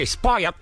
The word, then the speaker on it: spoiled: Aqui, spoiled. Você engole o finalzinho.